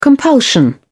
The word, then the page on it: compulsion: British English